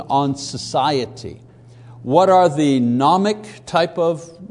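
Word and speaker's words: gnomic: Number one, nomic, don't pronounce the G, nomic, meaning knowledge.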